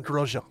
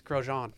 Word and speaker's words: Grosjean: Roman Grosjean. – Yes, Grosjean. – Yeah, I couldn't pronounce that.